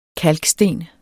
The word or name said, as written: kalksten